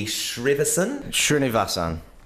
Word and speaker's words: Srinivasan: Srinivasan.